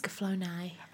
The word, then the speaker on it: Escaflowne: Am I pronouncing that correctly? – It's Escaflowne.